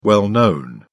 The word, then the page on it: well-known: British English